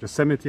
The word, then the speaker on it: Yosemite: Yosemite ist Yosemite. die richtige Aussprache.